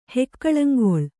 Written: ಹೆಕ್ಕಳಂಗೊಳು

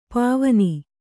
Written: ಪಾವನೆ

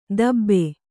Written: ದಬ್ಬೆ

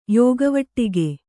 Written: ಯೋಗವಟ್ಟಿಗೆ

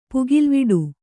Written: ಪುಗಿಲ್ವಿಡು

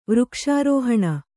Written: ವೃಕ್ಷಾರೋಹಣ

